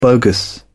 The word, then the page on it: bogus: British English